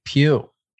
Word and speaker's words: Pugh: If you're eating someone's puss, I don't think you want their last name to be Pugh.